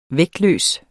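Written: VÆGTLØST

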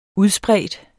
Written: UDSPREDT

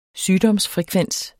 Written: sygdomsfrekvens

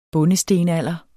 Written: bondestenalder

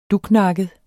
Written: duknakket

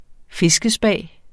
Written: fiskespa

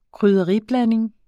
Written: KRYDDERIBLANDING

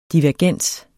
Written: DIVERGENSEN

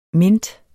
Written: mint

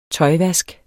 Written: tøjvask

